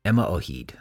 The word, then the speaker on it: Yma o Hyd: and can you pronounce it for me? It's it's the one that was sung by the folk singer. At, yeah, yeah. It's Yma Yma o Hyd. Emma O'Heed. Okay. Emma O'Heed.